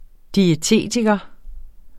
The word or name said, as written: diætist